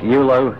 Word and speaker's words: Ewloe: Aston is spelled A-S-T-O-N. Aston Park, Eulo, spelled E-W-L-O-E, Clwyd in North Wales.